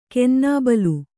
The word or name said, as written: ಕೆನ್ನಾಬಲು